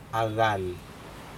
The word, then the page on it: acal: Aneityum